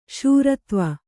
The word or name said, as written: ಶ್ರೀದೇವಿ